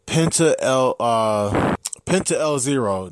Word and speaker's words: Penta El Zero: And I will be at Atlantic City that night to catch SWF Pro Wrestling, Alberto El Patron versus Penta El Zero. That's how you say it.